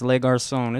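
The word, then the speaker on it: Le Garçon: Yes, Le Garcon. It's French.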